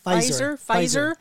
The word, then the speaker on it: Pfizer: Pfizer? Yes. – Well, it starts with a P, Pfizer. – I think the P is silent, just like in P.